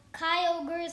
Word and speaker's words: Kyogre: So, Kyogre is how you say it.